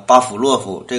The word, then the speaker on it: 巴甫洛夫: 今天我们要介绍的这只神兽是巴甫洛夫的狗。注意啊，这个巴甫洛夫这个字儿读“ 斧”，斧头的“ 斧”这个音，而且这个字儿就这一个读音。